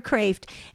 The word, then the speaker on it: Kreeft: Okay, so before we let you go, Joan Carol Cruz, Angels and Devils, or Peter Kreeft's book, Peter Kreeft, and it's K-R-E-E-F-T. Peter, he pronounces it Kreeft.